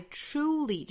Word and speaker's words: truly: 后面半句的难点呢就在 truly 这个词上。很多同学又会把它对应成汉语里面那个触角的触哈，就是读成了 truly。一定要注意这个，其实关键点在于这个 r 的发音。